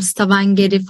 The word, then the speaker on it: Stavanger: Ben Norveç'e geldiğimde hani bana Oslo tabii ki de bir şehir ama şimdi karşılaştırıyorum Oslo dışında Bergen'i eğer doğru telaffuz ediyorsam Stavanger'i falan hadi.